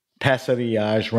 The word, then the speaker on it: Passerillage: and Passerillage. Is that the pronunciation? – Passerillage, Rancho Real Vineyard, and it's made in the Santa Maria Valley.